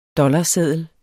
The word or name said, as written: dollarseddel